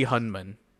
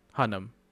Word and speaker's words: Hunnam: Charlie Hunman. Hunnam.